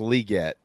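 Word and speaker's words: Legette: He says his last name is actually pronounced Leggett.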